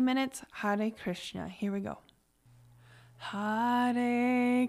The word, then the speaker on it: Hare: and then we'll do the Hare Rama part tomorrow. So all we are saying today is Hare Krishna, and it's Hare, which is H-A-R-E, and it's, I pronounce it with a soft R, Hare Krishna.